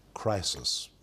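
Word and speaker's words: crisis (κρίσις): The word judgment in Greek is the word pronounced crisis.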